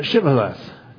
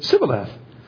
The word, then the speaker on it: Shibboleth: "Then said they unto him, 'Say now Shibboleth.' And he said, 'Sibboleth,' for he could not frame to pronounce it right.